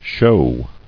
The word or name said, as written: Shew